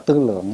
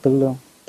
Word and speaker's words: tư lương: Chữ tư lương này á, nó là vốn là tư lượng, nhưng mà mình thường đọc là tư lương.